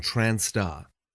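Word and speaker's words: trans*: Trans and trans star, used as an abbreviation of transgender or transsexual, or as an umbrella similar to transgender. Some people use trans with the asterisks, pronounced trans star.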